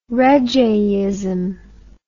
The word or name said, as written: reggeism